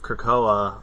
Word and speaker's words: Krakoa: And so they would send them to the island of Krakoa. Is that how you pronounce it? – You yeah, there's another nod, Krakoa. Yeah.